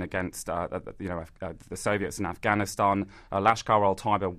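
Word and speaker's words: Lashkar-e-Taiba: Well, the key suspects are Lashkar al-Taiba. – Lashkar al-Taiba.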